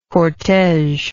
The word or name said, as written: cortege